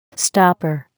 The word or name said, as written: stopper